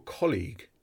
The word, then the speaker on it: colleague: Your colleague is someone you work with. This word has the long E sound.